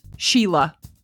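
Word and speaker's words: Síle: Or S-I-L-E, we'd say Sile. It's pronounced Sheila.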